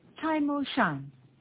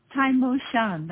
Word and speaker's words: Tai Mo Shan: Yes, Tai Mo Shan. – Sorry? – Tai Mo Shan, that's the name of the mountain.